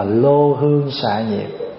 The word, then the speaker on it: lư hương: Có những cái câu thí dụ như mình mở đầu vô là lư hương xà nhiệt, thì mà bắt đọc là lô hương xà nhiệt, pháp giới mông hương.